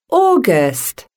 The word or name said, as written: August